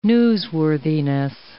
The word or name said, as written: newsworthiness